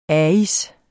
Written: ais

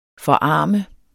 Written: forarme

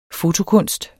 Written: fotokunst

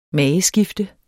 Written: mageskifte

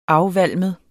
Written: afvalmet

